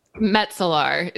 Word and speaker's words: Metzelaar: So it's pronounced Metzelaar. It's a Dutch Metzelaar. Last name. Double A's.